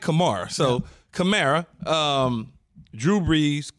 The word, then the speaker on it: Kamara: Given the fact that you have Michael Thomas, you have Emmanuel Sanders, Kamara. If I said it right. – Kamara, Alvin Kamara.